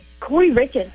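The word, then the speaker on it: Kouri Richins: In Utah, is it Corey Richens? Is that the way you pronounce it, Corey Richens? – Yes, Corey Richens.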